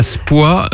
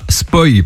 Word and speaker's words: Spoy: Ah, Émilie qui habite à Spoy dans l'Aube et non pas Spoy parce que ça s'écrit S-P-O-Y.